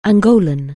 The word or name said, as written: Angolan